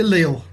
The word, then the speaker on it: Lille: Lily. – Lille. – Lily. – Lille. Did you say Lille? That's how they pronounce it.